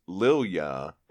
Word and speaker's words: Lilja: If that's the case, it's pronounced Lilja, and it means lily, the flower.